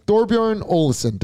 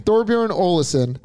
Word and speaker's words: Thorbjørn Olesen: I'm going to go with a recent champion on the DP World Tour, and that is Thorbjørn Olesen. Did I say that right? – Yeah, you did. – Thorbjørn Olesen. – Olesen.